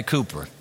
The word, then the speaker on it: Cowper: The English poet and hymn writer, William Cowper, it's spelled Cowper, but he went by Cooper, gave to the Christian church some of her most beloved hymns.